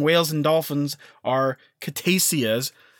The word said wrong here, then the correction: cetacean: Oh, Dave, I'm an idiot, and I was completely wrong in how to say it. – Oh. – It's actually pronounced cetacean. I wasn't even closer. – Good job. – So now it's cetacean.